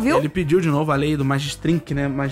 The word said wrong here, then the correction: Magnitsky: Como é que fala isso? – O quê? – Magnitsky. Ele pediu de novo a lei Magnitsky.